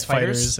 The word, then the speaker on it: FighterZ: Yeah, is that how you say it? It's Fighters. – God damn it. – Yeah. I hate that. – I hate it a lot. – Because it's based on Dragon Ball Z, not Dragon Balls.